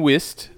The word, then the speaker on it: Whist: So it's Martin Wist. I think that's how you say his name, W-H-I-S-T.